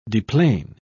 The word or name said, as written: deplane